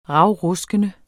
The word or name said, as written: ravruskende